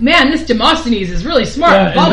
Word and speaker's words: Demosthenes: Demosthenes.